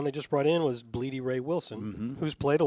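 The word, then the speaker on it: Blidi: B-L-I-D-I. I think that's how it's pronounced. Bleedy.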